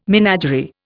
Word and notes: menagerie: Indian

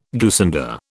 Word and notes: goosander: British English

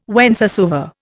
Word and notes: whencesoever: Indian

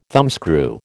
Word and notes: thumbscrew: American English

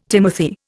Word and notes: timothy: Indian

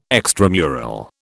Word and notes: extramural: British English

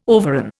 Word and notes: overran: Indian

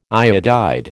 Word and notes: iodide: American English

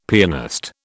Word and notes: pianist: British English

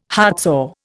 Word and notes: heartsore: Indian

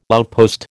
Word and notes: outpost: American English

